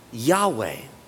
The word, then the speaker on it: Yahweh: And so today, well, we now add a couple of vowels to those Hebrew letters, and we would typically pronounce then God's name as Yahweh, which most scholars believe to be pretty darn accurate.